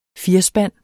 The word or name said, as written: firspand